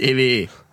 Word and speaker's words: EWE: spricht man anders aus! – Weil niemand halt... Niemand kommt aus Oldenburg, aber Yannick Brunke kommt hier aus der Area und war so, Digga, heißt EWE.